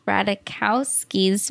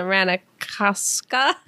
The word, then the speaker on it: Ratajkowski: oh, yeah, we did find out that you can say her name both ways, and then the Ratajkowska is the Polish way, but she still says it.